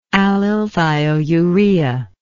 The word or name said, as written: allylthiourea